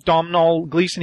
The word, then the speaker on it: Domhnall Gleeson: Also, don't forget, is it Domhnall or Domhnall Gleeson, the guy from About Time and... – Mm-hmm. Domhnall Gleeson.